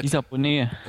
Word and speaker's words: Lisa Bonet: Lisa Bonet. – Lisa Bonet.